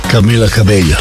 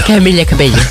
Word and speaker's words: Camila Cabello: Camila Cabello. – Camila Cabello. Ovo je kao Iberbajber. Sad, da li neko kaže Camila Cabello ili Camila Cabello, ja sam više za tu J opciju.